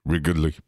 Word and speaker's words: Wrigley: Why'd you put extra syllable in it? – Wrigleyville. – Wrigleyville. – Wrigley. – I've been to Wrigleyville. Did I do it again? Right across the street from Wrigley. – Can't hear it anymore. – It's two syllables, bro.